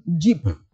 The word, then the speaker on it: geep: They say their hybrid is called Jeep. So the Jeep is spelled G-E-E-P, not J-E-E-P. Maybe your old Jeep, what you did. – G-E-E-P.